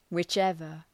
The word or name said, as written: whichever